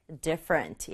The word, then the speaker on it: different: 이렇게 같지 않은 다른 점을 얘기할 때 different 얘기할 수 있고요. different 발음할 때는 제일 앞에 있는 D에 강조 줘서 different 이렇게 발음해 주세요.